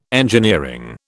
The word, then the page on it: engineering: British English